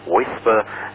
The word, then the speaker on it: WSPR: Der Ihnen bereits von interessanten Vorträgen bekannte Diplom-Ingenieur Hans Meyer, OE1 SMC, wird über WSPR, also Weak Signal Propagation Reporter, ausgesprochen WISPR, referieren.